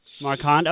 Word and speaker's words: Marquand: I don't know how he pronounces that, but it's. – I think it's Marquand. – Marquand, okay. Writing credits actually go to George Lucas and Lawrence Kasdan.